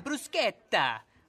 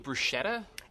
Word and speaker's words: bruschetta: I've heard good things about the prosciutto, also the caprese, also the mozzarella sticks, and the bruschetta, and the garlic bread. – Do you mean bruschetta? – Yeah, bruschetta. – Why are you saying it that way? – That's how it's supposed to be pronounced.